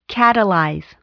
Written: catalyze

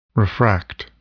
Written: refract

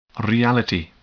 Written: reality

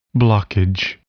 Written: blockage

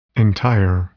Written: entire

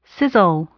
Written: scissile

